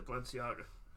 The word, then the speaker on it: Balenciaga: Oh, Balenciaga? – Ah, it's a Balenciaga.